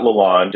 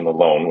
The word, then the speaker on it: Lalonde: Tampa Bay assistant coach Derek Lalonde, and it's not Lalonde, it's the traditional French pronunciation Lalonde, which was good to know today, has been announced as new head coach of the Detroit Red Wings.